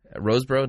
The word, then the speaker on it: Rosebro: Rosebro, did I pronounce it? Yeah, that's right. Just in case you know, I do know how to pronounce my last name. It's not Rosenberger, although I've heard all kinds of things.